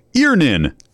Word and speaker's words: Earnin: E-A-R-N-I-N is pronounced Earnin. I don't know how else you would pronounce it, but they really want it clear that that's how to pronounce it.